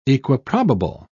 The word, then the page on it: equiprobable: British English